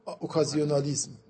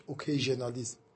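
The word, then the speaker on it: occasionalism: چون تو فلاسفه غرب مالبرانش در واقع همین نظریه رو داره که میگه اینا، اون تعبیرش چیز دیگه است، میگه علل موقعی، به تعبیر انگلیسیش چیه؟ اوکازیونالیسم. انگلیسی اون فرانسویه، تلفظ فرانسوی، انگلیسیش اوکیژنالیسم.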